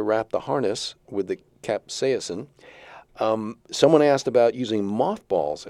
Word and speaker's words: capsaicin: We were talking about deterrence, and you mentioned the capsaicin. Is that how you pronounce it? – Capsaicin. – Capsaicin. Yeah.